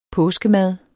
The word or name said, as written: påskemad